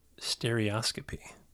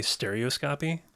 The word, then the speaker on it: stereoscopy: stereoscopy. And I'm saying that right because I looked it up. Some might say stereoscopy.